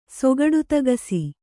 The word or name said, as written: ಸೊಗಡುತಗಸಿ